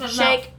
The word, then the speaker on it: Shayk: Shayk. Volvemos, shake, volvemos con nuestra pronunciación, pero le decimos Irina como si fuera amiga de nosotras.